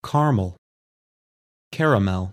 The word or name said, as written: Caramel